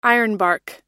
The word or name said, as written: ironbark